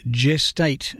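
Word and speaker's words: Gest8: My final selection today is a quirky but wonderful piece from one of Australia's more imaginative ensembles, an octet called Gestate. It's spelt G-E-S-T and then number 8.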